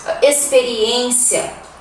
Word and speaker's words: experiência: Mas é assim, o X às vezes tem som de S. Por exemplo, na palavra sexta, sexta-feira, né? Sexto, sexta. Experiência, expectativa. Se escreve com X, mas o som é S, ok?